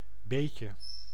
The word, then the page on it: beetje: [beːtʃǝ]; /beːtjə/